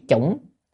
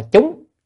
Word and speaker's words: chủng: Chữ chủng nếu mà theo từ điển á, thì cái chữ này á, chủng là mặt trồng, còn có khi đọc là chúng chúng chư thiện căn cũng được, cũng ở trồng, nhưng mà ở Việt Nam các vị chư tổ thường đọc chủng luôn là trồng luôn rồi, trồng các cái thiện căn tức là các căn lành.